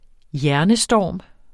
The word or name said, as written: hjernestorm